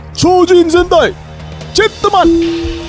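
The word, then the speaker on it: Chōjin Sentai Jetman: Chojin Sentai! Jetman! – That's how they say it in Japanese, yes. Chojin Sentai Jetman.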